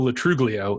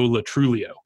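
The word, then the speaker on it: Lo Truglio: It is not Milo Ventimiglia and Joe Latruglio. It is Milo Ventimiglia and Joe Latruglio. It's like a soft / silent G.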